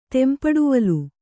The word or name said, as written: ತೆಂಪಡುವಲು